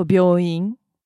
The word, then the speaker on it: 病院: I think you just pay attention to the accent and the syllables. 病 is one syllable for 病院, but 美容院, 美容, that's two syllables.